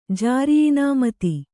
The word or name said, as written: ಜಾರಿಯಿನಾಮತಿ